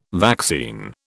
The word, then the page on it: vaccine: British English